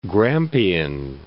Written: Grampian Hills

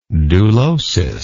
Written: dulotic

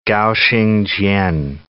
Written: Gao Xingjian

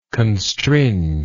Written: constringe

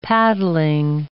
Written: paddling

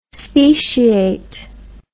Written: speciate

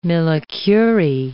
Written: millicurie

